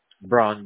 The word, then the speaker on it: Brown: Brown, B-R-O-W-N.